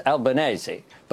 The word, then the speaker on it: Albanese: Christopher Pine actually got it right in terms of the Italian pronunciation. It's Albanese. But you don't say spaghetti bolognese, do you?